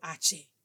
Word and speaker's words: hache: El poder de Hache, the power of Hache. You want to know what Hache is? Hache is como se pronuncia la letra H in Spanish. H is how you pronounce the letter H in the Spanish alphabet.